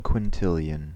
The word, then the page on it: quintillion: American English